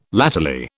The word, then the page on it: latterly: British English